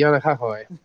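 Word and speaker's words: Yanakakoi (Keanakākoʻi): I Kana, Kanakakoi. – That was close. Um, the actual pronunciation would be Yanakakoi.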